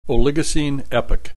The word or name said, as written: Oligocene Epoch